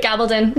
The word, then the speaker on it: Gabaldon: So for those not familiar with the Outlander Starz original TV series, based on a book of Dianna Gabaldon. I hope I said that right. – Gabaldon.